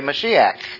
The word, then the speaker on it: Mashiach: The only way you could dress it up a little bit is to be a little more precise would be to say Mashiach, because that's the way the Hebrew is pronounced.